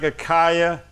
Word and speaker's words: Achaia: Baskaino, B-A-S-K-A-I-N-O. Baskaino. Baskai. Anytime you see A-I in a Greek word or in the Bible, really, it comes from Greek, you pronounce it I. You hear me? You pronounce the I like Achaia, A-C-H-A-I-A. The A-I is A-E, A-E, like they do in Spanish. A-E, Achaia. That's how you do it.